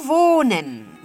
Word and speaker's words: wohnen: El verbo wohnen, wohnen, residir, wohnen. Aquí tenemos la H, como hemos dicho antes, la H no se pronuncia, simplemente se extiende la vocal.